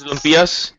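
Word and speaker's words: lumpia: Christmas lumpias. – Do you add those? – What's that? – Christmas uh, lumpias. Nah. How do you pronounce it? – Lumpias. yeah, yeah. I think you said it right.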